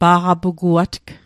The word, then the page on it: pa'qapuguatg: Mi'gmaq